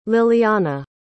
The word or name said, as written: lilliana